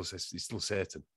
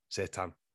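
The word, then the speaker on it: seitan: It's spelled S-E-I, I think, but it's still Satan. Satan.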